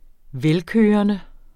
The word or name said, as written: velkørende